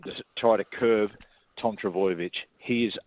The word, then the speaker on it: Trbojevic: Trbojevic.